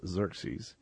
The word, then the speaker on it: Xerxes: And this king, his name was, Xerxes, and it's actually spelled X-E-R-E-S. You pronounce Xerxes.